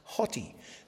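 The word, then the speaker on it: hoti (ὅτι): It starts with an omicron, an O, but it has a rough breathing over it. So it's pronounced hoti.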